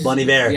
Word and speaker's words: Bon Iver: Is Bon Iver. – Yeah. – He puts me to sleep, but it's gorgeous. – Justin Vernon, that dude is he's a human quaalude. Like that's. – No, every Bon Iver song.